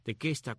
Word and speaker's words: tequesta: Otros indios de esta área son los llamados tequesta o tecuesta, de acuerdo con los menciones, lo pronuncias al inglés o el español, pero es tequesta, tequesta con Q, T-E-Q-U-E-S-T-A,